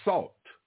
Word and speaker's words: sal: Money at ancient times used to be anything from cowrie shells to salt. Salt. Table salt. something like table salt. Salt. And that's where the word salary comes from. In the Latin, salt is pronounced sal. S-A-L.